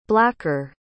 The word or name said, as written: blacker